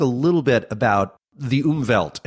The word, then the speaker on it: Umwelt: If time itself isn't necessarily a sense, there is this vast sensory realm that you and others refer to as the Umwelt.